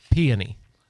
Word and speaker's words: peony: We did get confirmation from a listener. It is peony. – Peony. – Yeah, emphasis on the right syllable, the peony.